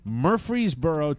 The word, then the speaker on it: Murfreesboro: I always get hung up on the pronunciation of Murfreesboro. – Yeah, it's M-U-R-F-R-E-E. – R-E-E, that's right. Murfreesboro, Tennessee, outside Nashville, and while he was under the truck, the jack gave out.